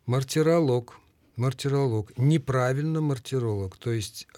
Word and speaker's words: мартиролог: Мартиролог. – Нет, мартиролог. Это ведь, да, древнегреческое ударение. Помните, физиолог, филолог, геолог и мартиролог до сих пор сохраняется в таком виде.